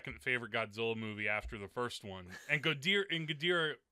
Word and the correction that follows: Ghidorah: Am I pronouncing that right? What? G- No. Ghidra. Ghidra. – Sorry. Ghidra. Damn.